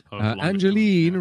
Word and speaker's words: Angeline: Picks Angeline rhymes with green.